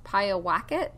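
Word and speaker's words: Pyewacket: origins of the word Pie Wacket. I've heard people also pronounce Pie a Wacket, because apparently there was a Native American tribe that had a word Pie a Wacket, so it might have had some roots there.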